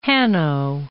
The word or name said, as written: Hanno